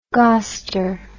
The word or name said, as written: goster